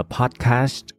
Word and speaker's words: podcast: đọc là podcast có nhiều người không hiểu là tại sao ở cuối phải đọc là sờ tờ nhưng mà thực ra là tôi quen rồi các bạn tôi quen rồi và đó là cái cách phát âm hợp lý các bạn nghe người nước ngoài nói nhiều các bạn sẽ thấy là podcast ha